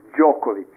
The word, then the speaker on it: Djokovic: Novak Djokovic.